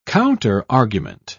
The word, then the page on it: counterargument: British English